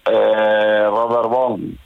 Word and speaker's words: Robert Vaughn: El agente de Cipoll, sí, sí, sí, es una serie con Robert Robert Vaughn, no sé si lo pronuncio bien. – Sí.